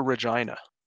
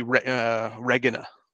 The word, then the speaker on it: Regina: Well, yeah, because I'll go Latin nerd on you here. G's in Latin are all hard G's. – Okay. – So it would be Regina, would be the proper, I think.